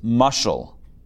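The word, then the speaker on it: mushal (משל): So the Hebrew word for parable is mushal. Mushal. Mushal. Okay, if you want to spell that in Hebrew, it's three letters. Mem, shin, lamed. Mushal.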